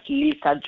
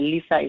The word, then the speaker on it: Lysa: I am on Facebook um, as Lysa Jones. My Lysa is spelled with Y. So it's L Y S A and then last name Jones.